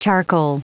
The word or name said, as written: charcoal